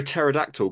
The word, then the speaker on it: pterodactyl: Why can't you hear a pterodactyl go to the bathroom? – Why? – Because the P is silent.